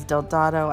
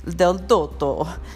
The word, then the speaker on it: Del Dotto: And I know everyone, you know, our American version is Del Dado. I believe the Italian way of actually pronouncing it is Del Dotto.